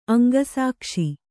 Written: ಅಂಗಸಾಕ್ಷಿ

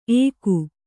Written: ಏಕು

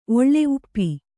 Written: ಒಳ್ಳೆ ಉಪ್ಪಿ

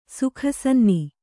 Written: ಸುಖಸನ್ನಿ

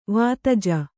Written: ವಿಧಿಜ

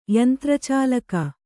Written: ಯಂತ್ರಚಾಲಕ

